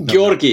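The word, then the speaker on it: György: And maybe moving from Durkheim, we can go to Georgi Lukács, who I might be butchering his name there. It's very Georgi, yeah. Or just you can just say George.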